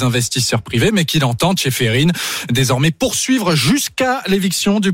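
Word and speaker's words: Čeferin: Seferin, le patron de l'UEFA, l'instance européenne du foot.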